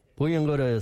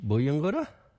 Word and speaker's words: buyungurra: Puyung Gura is spelled B-U-Y-U-N-G-U-R-R-A. Can you say Puyung Gura, turtle?